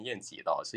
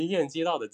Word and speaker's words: 晏几道: 刚刚在前面也和大梦老师在聊这个事情，因为他名字中的这个鸡是接近的意思，对，用简体词是容易混淆嘛，所以我们读他的名字也不要读错了，不要读成晏几道，是晏几道。其实就目前可见的史料来看啊，对于晏几道的记载还是非常少的，我们也只能从他的词集和关于他的几则轶事中去窥见一二，对于他的一个整体的形象。